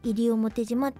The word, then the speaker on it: 西表島: あのー、まあ、西表島って西に表に島で西表島って読むんですけど、まあ、なんで私がこれ読めたかって言いますと、あの、なんかの教科書に、その、西表山猫っていう西表島